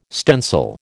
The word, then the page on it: stencil: American English